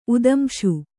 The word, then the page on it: ಉತ್ತಂಸ: uttamsa